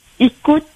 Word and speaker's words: Icoute: Écoute, révolte, Marie, Margaret. – Écoute, ça s'écrit comment? – I-C-O-U-T-E. – I-C-O-U-T-E, oui.